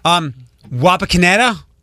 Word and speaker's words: Wapakoneta: Wapakoneta. – Yes, you said it right this time.